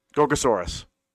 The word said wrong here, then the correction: Gorosaurus: No. I think you're thinking of Gorosaurus.